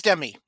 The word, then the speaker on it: Demme: It is Demme. Jonathan Demme is how they pronounce their name.